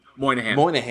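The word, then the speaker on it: Moynahan: but Bridget Mahonin? – Moynihan. Yeah, Bridget Moynihan.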